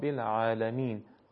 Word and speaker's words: العالمین: حرف عین حالا نمیدونم چه ظاهری داره که آدم فکر میکنه که حتما باید درست پرحجم بشه، نه، اتفاقا حرف عین وقتی فتحه داشته باشه یا بعدش الف مدی باشه، فتحه و الف مدی درست پرحجم تلفظ نمیشن. سبحان ربی العظیم و بحمده الحمد لله رب العالمین، عالمین دیگه نمیشه.